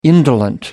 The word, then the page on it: indolent: American English